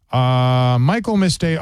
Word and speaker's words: Michaelmas: It's like the word Christmas, but with the word Michael. Michaelmas Day.